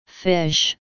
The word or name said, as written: fish